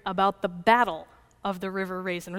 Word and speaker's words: River Raisin: I used to live in Monroe County, which is a couple of miles from the River Raisin. That's how they say it. It's not the Raisin River. Don't say the Raisin River.